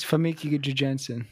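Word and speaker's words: Famke Janssen: Fucking, how do you say Famke? – I believe it's Femke. – It's Famke Jensen.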